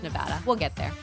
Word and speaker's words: Nevada: That's not how you say it. – Thanks for having me. – Will Hill, everybody. It's Nevada. I need to put a PSA out there. Ladies and gentlemen, Nevada.